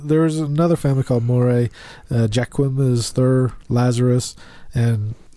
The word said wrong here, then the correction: Joaquin: isn't that Joaquin? – He's pretty much... – Joaquin. – Is that how you pronounce it?